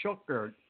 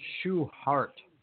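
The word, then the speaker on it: Schuchart: I looked up the correct pronunciation, even though I can't pronounce correct right now. Logan Schuchert, or Schuhart, or whatever I was trying to pronounce it as. It's pronounced Schuhart.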